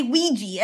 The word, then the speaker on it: Ouija: I think it should be Ouija, but I always say Ouija as if it was spelled W-E-E-G-E-E. Ouija.